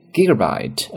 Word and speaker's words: gigabyte: 那么就是 include thirty two gigabyte of storage，三十二 G 的一个内存。 The mid-tier model，那么再往上中档的手机的内存呢，has one hundred twenty eight gigabyte。